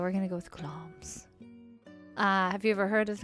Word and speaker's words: Glamis: It is spelled like Glamis in my Ohio nasally accent. Glamis, but is pronounced Glom's.